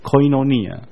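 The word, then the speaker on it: koinonia (κοινωνία): 这些都是其实都从同一个希腊文来，那个希腊文读作 koinonia。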